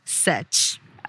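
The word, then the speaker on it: Sech: Setch.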